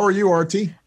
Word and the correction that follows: Aarti: Did I pronounce that properly? – Almost perfect. Like a T-H, Sanskrit T, Arthi. – Arthi.